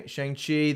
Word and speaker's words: Shang-Chi: How do you say it? – Shang-Chi. – Shang-Chi, The Legend of the Ten Rings.